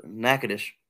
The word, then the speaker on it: Natchitoches: Natchitoches, wherever you lived. – Hey. – Is that how you say it? – So, Natchitoches. – Oh, sorry. – Get it right, boy.